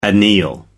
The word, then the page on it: anneal: American English